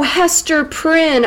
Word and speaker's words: Hester Prynne: What did you say her name was? Esther Prine? Oh, Hester Prynne, okay.